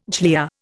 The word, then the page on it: glia: Indian